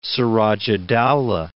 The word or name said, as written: Siraj-ud-Dawlah